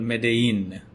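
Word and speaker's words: Medellín: I watched a show that showed me how to pronounce it. It's called Medellin.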